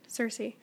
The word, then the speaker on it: Circe: But then Circe's son, is that how you say it? – Circe.